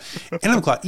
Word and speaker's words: Enumclaw: Enumclaw, E N U M C L A W.